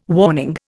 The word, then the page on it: warning: Indian